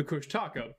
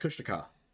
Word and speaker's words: Kushtaka: And the Kush Tussie. – Yes. No, the Kush Taka, but... – Well, yeah. You know, I've been informed it's actually pronounced Kushtaka. – Well, there we go. Kushtaka.